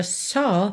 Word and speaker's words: saw: And if you saw my love, atenção à pronúncia dessa palavra só, passada do verbo see, tá? A gente tem S-A-W, mas não é saw, e sim só. Pensa num O com acento agudo mesmo, tá?